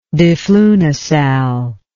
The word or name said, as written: diflunisal